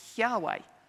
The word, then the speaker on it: Yahweh: So it's spelled Y-H-W-H. So no vowels between it. And we guess that the way that it was pronounced, or the best kind of knowledge that we have about the way that it's pronounced, is Yahweh.